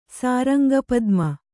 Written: ಸಾರಂಗಪದ್ಮ

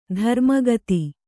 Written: ಧರ್ಮಗುತ್ತಿ

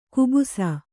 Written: ಕಬ್ಬಸು

